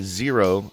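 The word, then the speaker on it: Xero: We are going to be meeting with the CEO of Zero Shoes.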